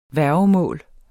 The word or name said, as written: værgemål